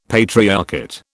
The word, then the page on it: patriarchate: British English